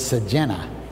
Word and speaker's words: Sejinga: It's spelled Sejinga, but it's Sejena.